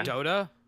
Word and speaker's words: Dota: D-O-T-A. – Yeah. Dota? – Dota.